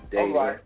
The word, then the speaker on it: Dayton: Pronounced Dayton, D-A-Y-T-O-N, just like Dayton, Ohio.